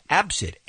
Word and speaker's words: ABCD: Yep, Absid. A-B-C-D is how it's spelled. – See, I'm saying stop that. You stop doing that. Don't name your kid A-B-C-D.